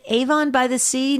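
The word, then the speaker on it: Avon-by-the-Sea: Let's hear what Mary has to say on, is it Avon by the Sea, New Jersey, on Sirius XM 130. Did I say that correctly, yeah. – Mary? Okay. – You did.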